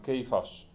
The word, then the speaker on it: Kefa: En het Aramees staat er dan Kefas. Tenminste, bij ons in de Nederlandse vertaling wordt het weergegeven als Kefas. En zo wordt het ook uitgesproken in het Aramees, Kefa.